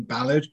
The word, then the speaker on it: Ballard: Yeah, almost Bell End, but Bellard. – Very close. I have kind of resigned myself to it being Ballard because that's how Americans say it. But it's funny, after I was teaching my fiance, now wife, how to say it, she was a lot more militant about it than I am.